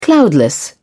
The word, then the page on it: cloudless: British English